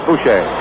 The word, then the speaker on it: Bouchee: Coming up to the plate now will be Ed Bucci. He spells his name B-O-U-C-H-E-E. Edward Francis Boucher, or Bucci. I think the pronunciation for his name actually is Boucher.